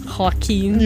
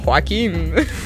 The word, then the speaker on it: Joaquin: Joaquin. – You said it like Joaquin.